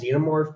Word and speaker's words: xenomorph: It's pronounced xenomorph, but you can just call it the alien.